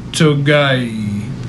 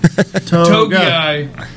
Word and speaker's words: Togiai: And then I'm giving you Ohio State defensive tackle Tommy Togiai. – I knew there was an extra vowel sound. Togiai.